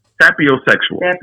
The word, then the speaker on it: sapiosexual: Saposexual. Most people are not aware of the term, but saposexual people. – Say that again? Saposexual? Did I say it right? – Yes. Sapiosexual.